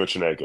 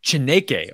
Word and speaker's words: Chineke: Uh, Chima Chineke, yeah. Chineke.